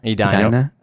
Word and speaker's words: Edina: A lot of the times the top players, when they come in state, they just sign up for Edina. And then knowing that the tennis program is just so good. – Wait, how do you can you pronounce it again? Edina? – Edina.